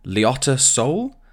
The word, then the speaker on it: Liotta Soul: This first band are called, I don't know if I'm pronouncing this right, it's Liotta Soul. First word, L-I-O-T-T-A, Liotta. And then Soul, as in the place in South Korea.